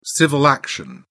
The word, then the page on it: civil action: British English